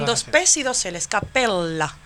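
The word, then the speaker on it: cappella: Que siempre hay que acordarse, Rafael, de que se pronuncia capella con dos P's. – Ajá. – Porque si no estaríamos hablando de capella, que es una cabra.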